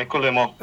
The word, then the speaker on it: Écoulement: You mentioned there's a work, it's mispronounced, it's Écoulement? – Yeah, Écoulement.